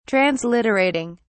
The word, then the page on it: transliterating: British English